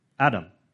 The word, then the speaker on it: Adam: And the reality is that Adam is just a transliterization of the Hebrew word for man, Adam. That's how you say it in Hebrew. We all just learned a Hebrew word, Adam.